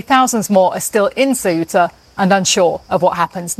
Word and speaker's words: Ceuta: So that's Ceuta.